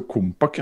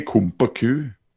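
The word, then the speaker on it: Compaq: Ja, det uttales jo kompak, ja. Ikke kompaku. Kompaku.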